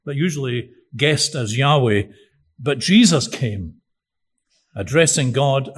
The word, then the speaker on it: Yahweh: In fact, the name of God, usually written as YHWH, usually pronounced Yahweh, but the word was regarded as so sacred that it couldn't even be uttered by sinful human lips.